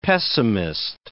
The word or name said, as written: pessimist